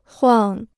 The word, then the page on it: 晃: huàng